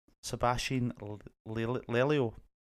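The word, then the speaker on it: Sebastián Lelio: Yeah, Sebastian Lelio.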